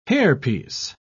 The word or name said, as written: hairpiece